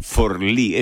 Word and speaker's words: Forlì: Sí, y Forlì, en realidad es Forlì, es un nombre... – Ah, Forlì se pronuncia, sí, es un nombre raro, no, imagínate.